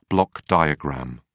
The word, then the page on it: block diagram: British English